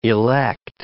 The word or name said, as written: elect